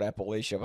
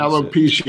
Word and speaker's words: alopecia: And then on stage this year, he made a joke about I want to call it Appalachia, but I know that's not how you actually pronounce alopecia.